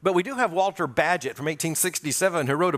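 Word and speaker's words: Bagehot: And Walter Badgett, and by the way, you won't get his name by thinking about it. It's B-A-G-E-H-O-T. It takes the English to pronounce that Badgett.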